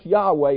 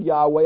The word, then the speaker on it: Yahweh: Now, of course, Yahweh is the covenant name for God, YHWH. We think it's pronounced Yahweh. We're not 100% sure.